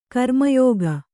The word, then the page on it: ಗ್ರಾಮ್ಯಕ: grāmyaka